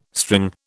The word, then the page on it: string: British English